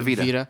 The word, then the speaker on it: Vita: Vita or how do you say that? – Yeah, the Vita.